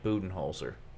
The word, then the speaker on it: Budenholzer: And he said Budenholzer. And I was like, come on, bro. It's Mike Budenholzer.